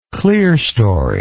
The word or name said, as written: clerestoried